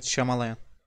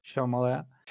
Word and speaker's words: Shyamalan: Shyamalan mıydı yanlış telaffuz etmiyorum değil mi yönetmeni? – Evet, Shyamalan. – Shyamalan.